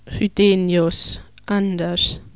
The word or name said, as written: Chydenius Anders